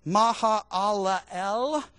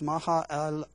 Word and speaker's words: Mahalalel: Mahalalel. Yeah, that's not an easy one. Is that five syllables? Mahalalel. I said it right the first time.